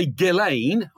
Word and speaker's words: Ghislaine: And that's why Ghislaine, I understand that's how you pronounce her name,